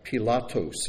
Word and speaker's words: Pilatos: Pilatos is how it's pronounced, the governor.